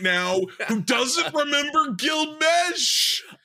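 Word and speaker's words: Meche: Gil Mechie, is that how you say it? – Mesh. – See, there you go. I'm dating myself. Gil Mesh.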